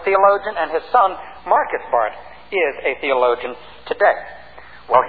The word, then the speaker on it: Barth: By the way, his name is properly pronounced Barth rather than Barth, but you do hear both of them.